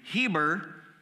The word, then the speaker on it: Eber: And because he is descendant of Eber, and Eber is actually pronounced more correctly Heber, Heber, Hebrew.